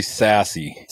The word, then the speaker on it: Sassy: It's actually Sassy.